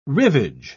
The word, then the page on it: rivage: British English